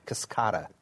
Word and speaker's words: Cascada: So there was another Buick, the Cascadia, the convertible. – Did I say it correctly? Cascada.